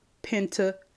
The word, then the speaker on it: Pentateuch: Okay, the Pentateuch, which is, I guess I'll spell it for you, P-N-T-A-T-E-U-C-H, which is pronounced Pentateuch.